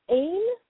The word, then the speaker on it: Aine: And I apologize, I'm going to butcher her name, but it's Ain Armour. The first name, A-I-N-E. It's Celtic. And the last name, A-R-M-O-U-R.